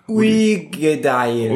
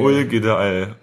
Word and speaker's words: Uigeadail: Und zwar gibt es den Artback, ja, wie spricht man das aus? Udi Gadel. – Ui Gedeil. – Ui Gedeil. Ui Gedeil. Oder so. – Gedeil, ja.